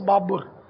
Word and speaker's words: بابر: بابر اتاری. یہ سب بابر بولتے ہیں بابر بولتے ہیں, تلفظ بابر, اور بابر کے معنی گھاس.